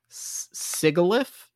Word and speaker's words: Sigilyph: Sigilyph.